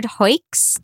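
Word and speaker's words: Huyck: The movie that the Next Real Film podcast is talking about next week is Willard Hoyks.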